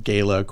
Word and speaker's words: Gala: And the choruses make up this movement of choruses called Gala Choruses, spelled like gala, but we like to pronounce it Gala.